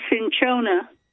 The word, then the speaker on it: cinchona: So, but you can find it's pronounced cinchona. – Cinchona. – And it's spelled like cinch, like you cinch up a horse. – Yep. – O-N-A. – Okay, C-I-N-C-H-O-N-A.